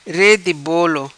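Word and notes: Re Di Bolo: patua

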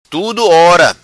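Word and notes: Tudo Ora: Macanese Patuá

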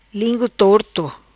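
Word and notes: Lingu Torto: patua

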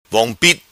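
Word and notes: Vong Pit: patua

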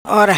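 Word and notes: Ora: patua

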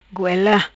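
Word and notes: Goela: patua

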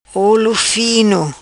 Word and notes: Olo Fino: patua

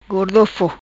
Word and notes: Gordofo: patua

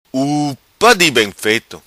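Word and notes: Upa Di Bem Feto: Macanese Patuá

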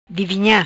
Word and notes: Divinha: patua

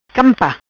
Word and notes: Campa: patua

